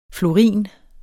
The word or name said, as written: florin